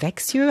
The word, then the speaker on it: Växjö: Du hast Soziologie studiert und neuere deutsche Literaturwissenschaft, Philosophie und theoretische Sprachwissenschaft an den Unis Konstanz und in Växjö.